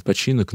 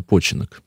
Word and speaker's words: починок: Иногда называют починок, но, наверное, все-таки правильнее починок. Почин на ударение.